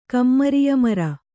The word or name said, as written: ಕಮ್ಮರಿಯಮರ